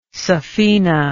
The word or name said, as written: saphena